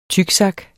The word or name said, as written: tyksak